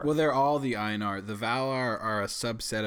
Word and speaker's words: Ainur: So like, yeah, like you said, a lot of the Ainur, am I pronouncing that right? Ainur? – No. There's no Enya in it. It's just Ainur.